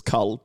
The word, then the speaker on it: Kull: Today, we are looking at the Vocaloid Kull, who I'm pronouncing as Kull because it is short for culture, so Kull.